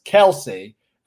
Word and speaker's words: Kelce: Travis Kelce put out that his name is actually pronounced Travis Kelce.